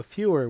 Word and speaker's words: Feuer: That's how I'm pronouncing it. I'm sure, you know, there's other, but that's how it's spelled, F-E-U-E-R. So Feuer would be, not greater, but Feuer. Swing High, Swing Low, 1937 on Brunswick.